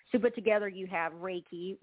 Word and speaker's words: Reiki: So Reiki is simply put, is it's pronounced Reiki, and it's spelled R-E-I-K-I.